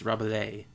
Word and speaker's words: Rabelais: Rabelais? Rabelais? – That's Rabelais.